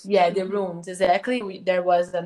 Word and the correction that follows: ruins: Uh, also, this is another another word I have difficulty to pronounce. Rooms, rooms. – Ruins. Yeah. Ruins. Ruins.